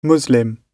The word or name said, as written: muslim